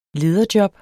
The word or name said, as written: lederjob